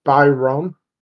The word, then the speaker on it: Bajram: Actually, it's Bajram. That's the way you would pronounce it.